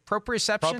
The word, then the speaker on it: proprioception: Proprioception? – Proprioception, yep.